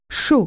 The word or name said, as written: hot